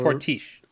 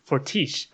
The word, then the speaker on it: Fortiche: Um, I apologies if I pronounce this incorrectly, but I think the studio that helped make this show is called Four Kite. For, Fortiche. – Fortiche. Okay.